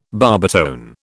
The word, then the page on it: barbitone: British English